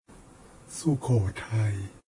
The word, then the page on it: Sukhothai: Thai